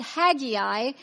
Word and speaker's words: Haggai: I'm pronouncing it Haggai. Bible scholars will pronounce it Haggai.